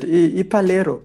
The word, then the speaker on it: Palero: Um, how do you pronounce it? Ipalero. Tell us, bro. – yeah, you got it right, bro.